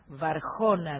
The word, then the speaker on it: Varhona: Les aconsejo que chusmeen la página de Varona, que se escribe V-A-R-H-O-N-A, Varjona sería, para ver lo que hay para fin de año.